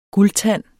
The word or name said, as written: guldtand